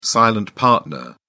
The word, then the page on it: silent partner: British English